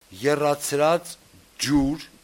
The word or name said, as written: eratsratsjur